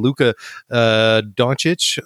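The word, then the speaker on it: Luka Dončić: And then Luka Doncic, I hope I can pronounce that properly.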